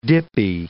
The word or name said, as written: dippy